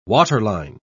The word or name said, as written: waterline